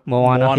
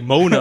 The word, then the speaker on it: Moana: How do you say in English? Mona? – Yeah, Moana. Yeah. – Oh, I was like, Mona.